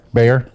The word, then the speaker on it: Bayer: It is 8:37, and I'm going to welcome our guest, Skylar Bayer, Ph.D. Did I say your last name correct, Bayer? – Yep. Sounds like the animal.